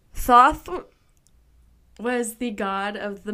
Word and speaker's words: Thoth: Sorry, Thoth. – Good pronunciation. – It's T-H-O-T-H.